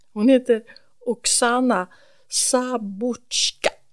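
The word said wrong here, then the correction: Zabuzhko: Nej, Sabuchko. – Mm-hmm. – Om jag uttalar det rätt.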